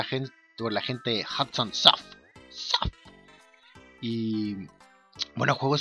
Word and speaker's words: Hudson Soft: El juego del Inspector Gadget de Super Nintendo era de la compañía Hands of Soft. en realidad nunca sabía cómo se pronuncia esa compañía. Hudson, Hudson Soft.